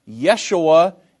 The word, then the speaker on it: Yeshua: Yeshua is the Hebrew pronunciation, and Jesus is the English pronunciation.